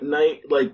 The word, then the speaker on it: Knight: It's like Knight, like Batman with a K, not Bruce Wayne.